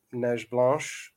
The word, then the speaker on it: Neige Blanche: I don't mind Neige Blanche at all. It's a grade 3 winner. Horse seems very nice French pronunciation, by the way.